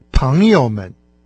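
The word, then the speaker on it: паньёмэн (朋友们): Таким образом, «друзья», в особенности в качестве обращения, по-китайски будет «паньёмэн». В этом трехсложном слове только начальный слог произносится с тоном, а именно со вторым тоном, а два последующих слога с легким. – Пхангьомен.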